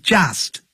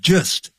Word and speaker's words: just: 不要念成 just 要念成 just 肚子饿的饿饿 just when I was just 1 year old 我才一岁的时候就搬到台湾来了 I began to learn English.